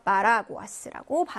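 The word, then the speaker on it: paraguas: 자, 5번 같은 경우는 에세로 끝났습니다. 예, 그래서 끝에서 두 번째 음절 모음에 강세가 와서 파라구아스라고 발음을 해 주시면 됩니다.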